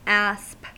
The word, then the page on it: asp: American English